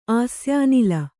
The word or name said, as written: ಆಸ್ಯಾನಿಲ